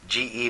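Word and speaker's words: ge (γῆ): The word translated as earth here in the Greek is ge, G-E, but it's pronounced ge.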